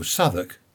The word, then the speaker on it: Southwark: He said, how would you pronounce it? It's Southwark. I said, oh, Southwark.